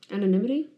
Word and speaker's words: anonymity: It's a five-syllable word. – Is it? – Anonymity. – That's too many syllables.